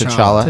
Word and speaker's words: T'Challa: They say it's T'Challa.